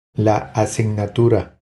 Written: La asignatura

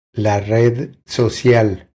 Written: La red social

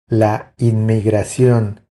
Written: La inmigracion

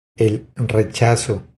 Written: El rechazo

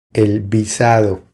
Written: El visado

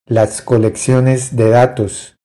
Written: Las colecciones de datos

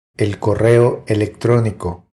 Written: El correo electronico

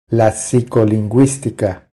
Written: La psicolinguistica

